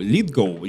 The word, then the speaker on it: Литгоу: Особенно четвертый сезон строится, и где э, Джон Литлоу, Литгоу, извините, я немного путаюсь его фамилия, э, играл...